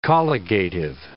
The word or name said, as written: colligative